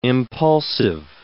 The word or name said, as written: impulsive